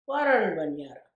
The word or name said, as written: Warrernben yarre